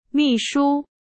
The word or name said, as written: 秘书